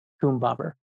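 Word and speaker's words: Koombobber: K-O-O-M as in Mary, and then bobber like a fishing bobber, B-O-B-B-E-R, coom bobber.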